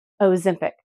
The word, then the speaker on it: Ozempic: Ozempic.